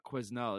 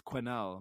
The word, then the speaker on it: Quesnel: Pre-show, Nikki's like, it's not Quesnel, it's Quenelle. I'm like, but there's an S in there. He's like, S is silent, buddy.